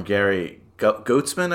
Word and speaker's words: Goetzman: But we do know that a lot of it's based on stories from Gary Goetzman, I believe it's pronounced, G-O-E-T-Z-M-A-N.